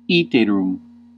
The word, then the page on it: iterum: Ecclesiastical; /ˈi.te.rum/; [ˈiː.te.rum̥]